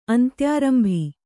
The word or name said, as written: ಅಂತ್ಯಾರಂಭಿ